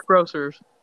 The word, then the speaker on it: Grocers: I don't know if I'm saying it right. Natural Groceries. – I think it's Grocers.